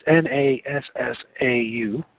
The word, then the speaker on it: Nassau: the House of Orange, I, I, don't know how to pronounce it, Nassau, it's N A S S A U.